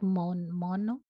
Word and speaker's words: Maono: It's like Mono. – M-A-O-N-O.